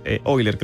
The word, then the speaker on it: Euler: Euler es un hombre, Leonard Euler, Euler creo que se pronuncia, pero... – Perfecto.